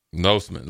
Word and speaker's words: Losman: Is it Lossman or Losman for the Bills? – I've always heard Losman. – Losman.